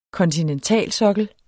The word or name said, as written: Kontinentalsokkel